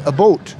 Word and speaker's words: about: They're very, very defensive about that. But there are about a half dozen words that they pronounce a little bit differently. Mm. For example, about, they say a boat.